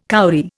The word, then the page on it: kauri: Indian